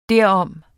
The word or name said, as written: derom